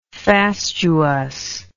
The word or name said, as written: fastuously